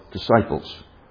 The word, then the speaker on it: disciples: A church is a body of disciples. D-I-S-C-I-P-L-E-S. You don't pronounce this, but it's still there. Disciples.